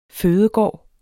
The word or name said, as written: fødegård